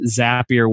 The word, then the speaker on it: Zapier: If you look down at the bottom, it used to be on the bottom left-hand side, it says Zapier makes you happier. They're actually telling you how to say their name.